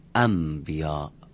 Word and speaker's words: انبیا: بعد بگیم انبیا. ما تو فارسی هم اینطوری میگیم، شنبه رو میگیم شنبه، پنبه رو میگیم پنبه، در برای اینکه راحت تر بشه تلفظ اینطوری تلفظ میکنیم.